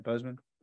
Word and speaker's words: Boseman: Did I say that Boseman or Boston? How do I say it? Boseman.